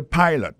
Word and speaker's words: pilot: Pilot là, c'est même mot pilote là, il est tiré E-A-D-L-I, il écrit P-I-L-O-T. Alors, il s'est prononcé I dans le cas ça. Alors, et puis, dans le mot pilot là, tout, après accent tombé sur le premier syllabe là.